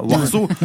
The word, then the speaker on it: Warsaw: Warsaw. non, non, mais ça se prononce comme ça.